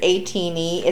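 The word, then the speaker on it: ATINY: Fandom name is ATEEZ spelled like tiny, but it's A-teeny.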